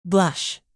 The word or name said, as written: blush